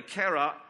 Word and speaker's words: cera: and cara, C-E-R-A, cara in Latin is how you pronounce it, meaning wax.